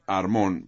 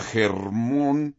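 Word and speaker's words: Hermón: H-A-R-M-O-N, Armón, porque en hebreo se pronuncia Germón.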